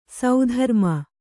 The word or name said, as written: ಸುಧರ್ಮೆ